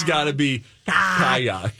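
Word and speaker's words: ka'ak: I think it's pronounced kayak. Kayak. – Kayak? How's it spelled? – K-A. – Uh-huh. – Apostrophe A-K. Kayak.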